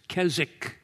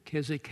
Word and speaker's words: Keswick: This is not just, as we sometimes talk about, let go and let God, sometimes referred to as Keswick theology. Keswick has a W in it, but it's a silent W, from that deeper life theology that was associated with that.